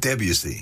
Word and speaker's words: Debussy: Debussy.